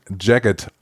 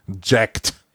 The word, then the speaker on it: Jagged: Ich habe wirklich nochmal nachgeschaut im Aussprache-Wörterbuch, wie man Jagged ausspricht. Und es ist Jagged, nicht Jacked. Wie auch gerne oft einmal gesagt wird, Jacked Alliance. Es ist Jagged Alliance.